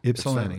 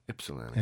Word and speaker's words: Ypsilanti: So I was like, I wonder if that Y is silent but yeah. – Ypsilanti. Yeah. That's how we say it. – Ypsilanti.